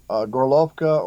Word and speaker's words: Gorlovka: Yeah, one of the first places they targeted was a little town or a city called Gorlovka or Horlo, depending on whether you're pronouncing it Russian or Ukrainian.